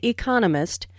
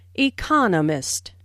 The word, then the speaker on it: economist: Notice the stress on economist. This content word has new information, so it is emphasized. There are four syllables in economist with the stress on the second syllable, economist.